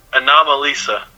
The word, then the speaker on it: Anomalisa: Anomalisa. – Anomalisa. That makes more sense. – Yeah, it's like anomaly, but with Lisa at the end.